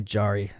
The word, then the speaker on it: Jarry: It's in reference to Ubu Raw, an avant-garde play by French writer Alfred Jarry, or probably Jarry is how you might pronounce that.